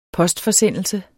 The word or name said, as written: postforsendelse